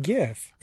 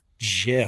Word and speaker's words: GIF: Number two, how can you pronounce it GIF? – Excuse me, GIF. There you go.